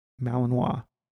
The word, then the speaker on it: Malinois: How do you say it? Malinois. – You got it.